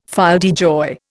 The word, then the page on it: fille de joie: Indian